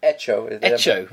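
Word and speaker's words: Hecho: Hencho in Venezuela. – Echo. – Really? – Yeah, they don't pronounce the first echo. It means made.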